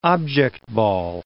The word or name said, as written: object ball